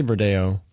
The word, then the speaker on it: Verdejo: The grapes usually used for it are Tinta Negra, Social, Verdejo, or Verdejo? Is that how you say it? – Verdejo? Yeah, I say Verdejo.